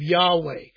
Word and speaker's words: Yahweh: The commands of Jesus are the commands of God, of Yahweh.